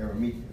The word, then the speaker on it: Arimathea: That's it. Arimathea. That's how you pronounce the name.